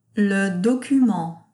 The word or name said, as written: Le document